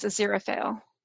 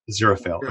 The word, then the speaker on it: Aziraphale: I think I might have actually said it wrong initially. I think it's Aziraphale. – Aziraphale, okay.